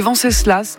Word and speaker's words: Venceslas: Venceslas, je ne sais pas si on prononce le S à la fin. – Ouais, moi je le prononcerais volontiers, ouais. Venceslas.